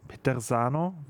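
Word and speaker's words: Peterzano: Petersano, wie spricht man das aus?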